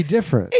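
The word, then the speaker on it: Diff'rent: It's not D-I-F-F-E-R-E-N-T. It's D-I-F-F apostrophe R-E-N-T. – Different. – Yeah, which is actually, if you think about it, how everybody pronounces it. – Different. Oh. Different. – Nobody pronounces the other E in different.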